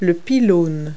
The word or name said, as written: pylon